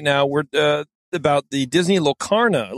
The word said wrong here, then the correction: Lorcana: Locarna, whatever. I don't know how it's said. – Never heard of it. – Uh, the card game. Yeah, There you go. Lorcana.